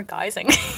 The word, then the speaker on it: guising: Geising or guising? – Guising, like guising. A guy, disguise. – Oh, disguise, guising. Guys.